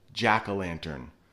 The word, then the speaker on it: jack-o'-lantern: And I know if you look up there, it has an O in it, but we really pronounce it jack-o'-lantern, more like an A instead of an O.